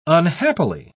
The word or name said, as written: unhappily